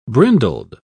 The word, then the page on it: brindled: British English